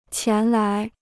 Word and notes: 前来: qián lái

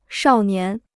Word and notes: 少年: shào nián

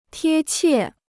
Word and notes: 贴切: tiē qiè

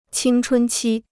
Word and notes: 青春期: qīng chūn qī

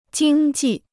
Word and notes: 经济: jīng jì

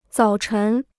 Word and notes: 早晨: zǎo chén

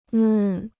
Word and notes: 嫩: nèn

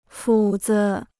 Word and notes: 斧子: fǔ zi

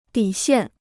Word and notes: 底线: dǐ xiàn

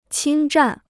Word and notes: 侵占: qīn zhàn

